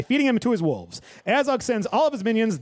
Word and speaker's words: Azog: Elsewhere, the orcs have made camp with Azog.